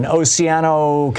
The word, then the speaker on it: Oceano: Nelson in Oceano, California. Oh, am I saying it right, Nelson? – Yes, you are.